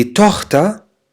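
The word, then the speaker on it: Tochter: دي توختا، شوفوا السي ها هون تلفظ مثل حرف الخاء في اللغة العربية، منقول دي توختا الابنة يعني البنت، يعني ماينه توختا يعني بنتي.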